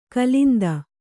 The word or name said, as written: ಕಾಲಿಂದಿ